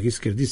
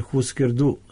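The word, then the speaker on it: Hüsker Dü: Tu postoji sad, mi nismo znali kako se zapravo izgovara njihovo ime, pa smo mislili da zbog onog umlauta se izgovara Hisker D, ali smo sad gledali ovaj film i vidjeli da Grant Hart sve vreme kaže Husker Du, da to prikide.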